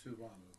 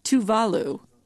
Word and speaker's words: Tuvalu: And then here's a country, an island country I'd never heard of in Tuvalu. Is that how you say it? – Tuvalu. – Tuvalu. Okay, I just got corrected by our producer.